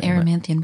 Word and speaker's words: Erymanthian: The fourth labor is Erymanthian boar. – The Erymanthian boar.